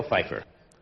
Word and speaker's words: Pfeiffer: Pues en realidad se debe pronunciar... – Michelle Pfeiffer. – ¡Anda! – ¡Michelle Pfeiffer! – Que es casi como Pfizer. – Casi como la vacuna.